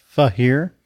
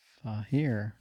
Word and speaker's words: Feher: Fahir. – F-E-H-E-R. – Fahir.